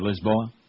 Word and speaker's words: Lisboa: By the way, that's how they say it over there, Lisboa, in Italy.